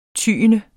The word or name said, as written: TYENDERS